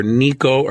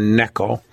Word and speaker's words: Necho: But one guy to remember is spelled Necho, N-E-C-H-O, but it's pronounced either Neco or Necho. I'll probably use Necho.